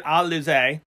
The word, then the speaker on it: Alize: I actually had to write it down because it looks like Amanda Elise. – It does. – But she pronounces it Alize versus Trish Adora.